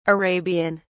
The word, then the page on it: arabian: British English